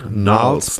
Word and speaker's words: Gnarls: Gnarls, ja, das G ist stumm.